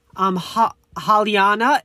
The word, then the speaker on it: Halyna: Director of photography, also known as DP, I do not want to mispronounce his name, Haliana, H-A-L-Y-N-A